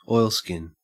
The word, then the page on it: oilskin: Australian English